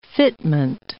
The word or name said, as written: fitment